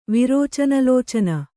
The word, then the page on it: ವಿರೋಚನಲೋಚನ: Kannada